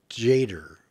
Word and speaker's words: Tjader: T-J-A-D-E-R. Jader. Okay, I'll go with Cal Jader. That's probably what it is.